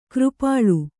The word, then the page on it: ಕೃಪಾಳು: křpāḷu